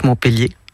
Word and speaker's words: Montpellier: Je suis née à Montpellier, que je prononce Montpellier, hein, à l'occitane.